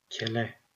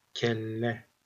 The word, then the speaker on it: kelle: Note that without the double L, the word in the middle would be kele. With a double L, it's kelle. The sound M.